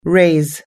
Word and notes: raise: us-english

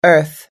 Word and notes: earth: American English

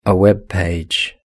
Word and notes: a web page: British English

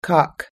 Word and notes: cock: us-english

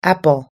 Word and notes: apple: us-english

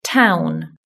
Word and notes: town: British English

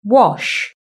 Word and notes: wash: British English

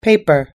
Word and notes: paper: us-english

